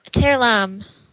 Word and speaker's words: Terlam: Terlam. – Terlam.